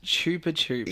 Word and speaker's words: Chupa Chups: It's apparently it's got its own website called Chupa Chup World. – Or Chupa Chup World. – Whatever. – It is Chupa Chup. – It's pronounced Chupa Chups. – Yeah! – And Chupa Chups in the UK. There are plenty of other pronunciations around the world.